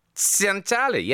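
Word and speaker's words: Tsantali: The word begins with a T, but then is immediately followed by an S. So I'm going, Santali? Santali. Yes, people have put thumbs up, like they know. Yeah, that's how you say it, Graham.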